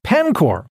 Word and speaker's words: Paincourt: Now, some would say Paincourt, Ontario, although it's pronounced Pancor, Ontario.